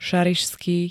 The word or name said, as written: ŠARIŠSKÝ